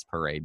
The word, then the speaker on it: Paré: I don't know if I'm saying it right, but I think it's Paré because he has an accent on the E.